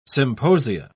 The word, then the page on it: symposia: British English